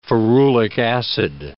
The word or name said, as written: ferulic acid